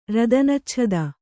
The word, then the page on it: ರದನಚ್ಛದ: Kannada